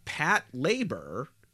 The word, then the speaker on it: Patlabor: Okay, it's pronounced Patlabor. It's a portmanteau of patrol and labor.